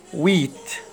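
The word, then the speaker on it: huit: رقم تمانية، ويت ويت الاش او الحرف لا ينطق، ويت.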